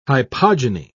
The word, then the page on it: hypogyny: British English